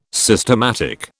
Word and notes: systematic: British English

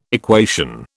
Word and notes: equation: British English

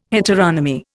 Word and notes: heteronomy: Indian

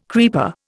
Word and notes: creeper: Indian

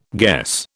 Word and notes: guess: British English

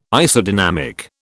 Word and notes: Isodynamic: British English

